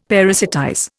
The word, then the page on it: parasitize: Indian